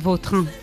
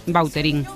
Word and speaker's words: Vautrin: Vautrin. Bueno. Pero aquí decimos bueno, Bauterín, Miriam. – Claro, sí, sí. ¿No? Pues, es que si me pregunta exactamente cómo se pronuncia, pues así, claro, pero sí. Yo ya soy Bauterín. – Ya. – Está españolizado total.